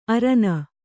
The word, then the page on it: ಅರನ: arana; Kannada